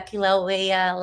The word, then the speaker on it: Kilauea: It's like a Kilauea lighthouse you pronounced it well.